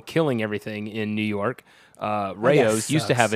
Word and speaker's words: Rao's: The RAO, Rouse. – That one's compliant. You're pretty compliant with most diets. – Now you guys don't want to talk about how to pronounce it. It's Rao's.